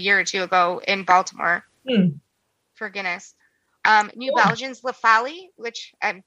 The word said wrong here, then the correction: La Folie: La Folie.